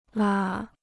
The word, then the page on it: 啦: la